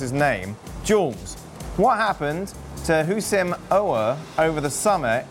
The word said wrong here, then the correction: Houssem Aouar: Who are you talking about? – Houssam. – I'm sorry. I shouldn't have. That was really mean. Houssam Aouar. – Yeah, Houssam Aouar.